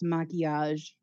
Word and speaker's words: maquillage: So apparently that word is pronounced maquillage. – Okay, maquillage. That checks out.